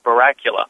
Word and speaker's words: Baracula: People tend to misspell it, but it's just like the president's last name. It's Baracula. So like Dracula, but Baracula.